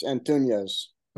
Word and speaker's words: Antunez: It's Antunez. – Okay, good. I just want to make sure I was saying it right, because there's not an Enya in there.